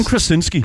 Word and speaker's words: Krasinski: Øh, og John Crash, øh, hvad, nu, hvordan udtaler du hans navn? John John Crash... Øh, Kras, John Krasinski.